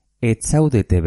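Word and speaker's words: ez zaudete: Pero se escribe ni es nago zarautzen. Ni enago zarautzen. Se obvia la Z. Yo no estoy en Zarautz. Zuek etzaudete Bermeon. Vosotros no estáis en Bermeo.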